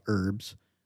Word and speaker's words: herb: At this point, we're scheduled to talk about herbs, but not in the way that most people talk about herbs. – You're pronouncing it correctly, too. The H is silent.